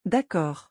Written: d'accord

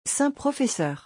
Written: 5 professeurs

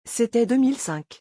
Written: c'était 2005

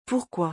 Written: pourquoi

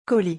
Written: colis

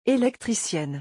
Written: electricienne

